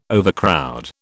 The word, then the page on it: overcrowd: British English